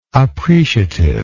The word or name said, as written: appreciativeness